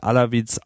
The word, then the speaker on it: Alawites: The Syrian government, mostly Alawites, how do you say that? Alawites? – Yeah, I say Alawite, yeah.